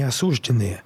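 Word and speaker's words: осуждённые: Юридически они уже не осужденные. Их, э, Осужденные, правильно говорить.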